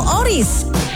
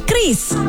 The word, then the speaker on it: QRIS: Ada yang menyebut QRIS, QRIS, atau ORIS. Kepala Grup Departemen Kebijakan Sistem Pembayaran DKSPBI, Fitria Irmi Triswati mengungkapkan, pengucapan yang benar adalah QRIS. Jadi, jangan salah ucap lagi ya.